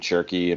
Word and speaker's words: Cherki: what's the French player's name Cherki am I saying that right I'm okay mm-hmm. so for once I'm not mispronouncing it